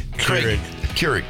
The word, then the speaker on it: Keurig: Johnny brought in a coffee maker because Krieg. Keurig. – Yeah. Keurig. Keurig. Yeah. – You don't even know how to say it.